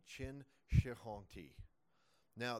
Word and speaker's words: Qin: The first is the Qin dynasty. Qin is spelled Q-I-N. And it's not pronounced quite Qin, but that's close enough for our purposes.